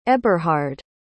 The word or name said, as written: eberhard